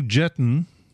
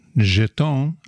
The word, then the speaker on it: Jetton: We're going to start off with some new tracks from, let's start out with Lou Jetton, Jeton, I think is the way he wants the name to be pronounced, because they spelled it out phonetically on the information sheet.